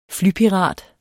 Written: FLYPIRATER

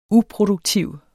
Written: uproduktiv

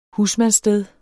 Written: husmandssted

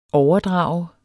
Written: overdrager

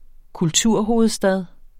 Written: kulturhovedstad

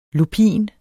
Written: LUPIN

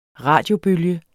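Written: RADIOBØLGER

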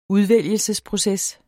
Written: udvælgelsesproces

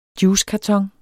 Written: juicekarton

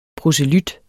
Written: PROSELYTTERS

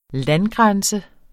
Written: landgrænse

